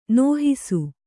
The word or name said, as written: ನೋಹಿಸು